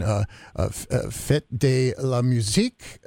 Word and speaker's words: Fête de la Musique: It's part of the international, and you're going to need to help me, Adrian, Fête de la Musique, I think.